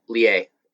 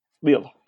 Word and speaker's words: Lille: And he was at Lille in Liga. – Your pronunciation is really bad, but yeah, I'll give it to you. 4-0. Lille. Yes, for Lille.